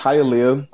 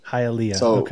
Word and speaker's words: Hialeah: It's Hialeah. – Hialeah.